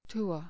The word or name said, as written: tour